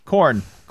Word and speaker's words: Khorne: Sorry, did you say Khorne? – Khorne. – Okay. All right. – With a spell it, please. – Yes. – With a K. – K-H.